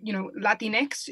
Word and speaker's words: Latinx: The sort of cutesy little helpful hint for folks that I've seen circulate a lot is it Latinx, it rhymes with Kleenex. So if you can say Kleenex, you can say Latinx.